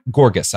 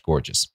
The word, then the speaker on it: Gorgas: Born Gorgas, I guess. G-O-R-G-A-S. I don't think it's pronounced gorgeous.